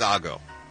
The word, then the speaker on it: Ago: It's spelled Igo, but I know it's not pronounced that way. – Okay. – I think it's Ago.